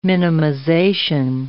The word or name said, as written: minimization